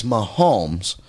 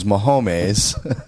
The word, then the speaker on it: Mahomes: Yeah, and I'm going to keep reminding you that you do not know how to say the quarterback's name. – What's his name? – It's Mahomes. But what she was calling him was Mahomes.